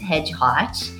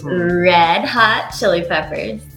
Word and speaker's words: Red Hot Chili Peppers: Today, we're talking about, and it's not pronounced Hedgehog. It's Red Hot Chili Peppers.